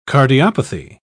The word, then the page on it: cardiopathy: British English